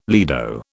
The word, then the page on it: lido: British English